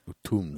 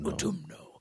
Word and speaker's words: Utumno: It's called Utumno, which I think is like a really cool name. – Utumno. – Utumno. – Are there tombs there? – No, I don't think so. It's spelled U-T-U-M-N-O.